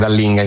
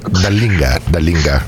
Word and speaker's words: Dallinga: La Chicca non è una fan di Dallinga, ma Dallinga come volete chiamarlo voi, io ancora metto sempre l'accento sulla prima A,